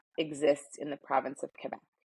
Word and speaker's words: Quebec: Quebec? You say Quebec. I think Americans say Quebec because we Q-U very hard, but you say Quebec.